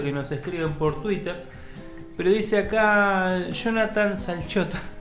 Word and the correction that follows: Slachota: No, no, no, Slachot. Jonathan Slachota.